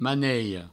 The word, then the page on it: manelha: maneio; maniho